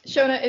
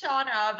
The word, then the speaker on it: Shona: It's pronounced Shona, but I'll let it pass. It depends on which region in the world you're from. Like, there's two interpretations, but all good.